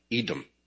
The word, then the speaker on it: Edom: Edom.